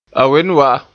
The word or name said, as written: wen